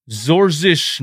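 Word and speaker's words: Georges: Doc Rivers went with George Niang to pronounce it in his father's native language, Zorzish Niang.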